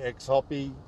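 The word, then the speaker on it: Xhoppy: If you look for Gavin Cramp or Choppy, people call me X Hoppy because it's a Choppy spelt with an X, which the X is next to the C on the keyboard.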